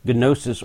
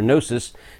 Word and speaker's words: gnosis: a more intense knowledge than just gnosis or gnosis, depending on whether you want to pronounce the G or not.